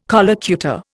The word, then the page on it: collocutor: Indian